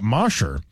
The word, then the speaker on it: Mosher: I wouldn't know, but Mosher, because mosh pit, MOSHER.